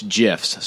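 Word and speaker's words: GIF: And this story is about GIFs, not GIFs, folks. It's GIFs, soft G.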